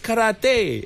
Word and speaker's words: karate: So how do you actually pronounce it? It's karate. – Yeah, because it's Japanese, right? – Correct. It's karate. It's not karate.